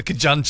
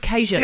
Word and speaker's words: Cajun: My mate Lindsay said he asked for the Kajun chicken. – The Kajun. – I was like, Kajun. – Instead of Cajun.